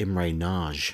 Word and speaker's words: Imre Nagy: I believe it's pronounced Imre Nagy.